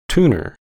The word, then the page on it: tuner: American English